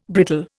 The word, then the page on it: brittle: Indian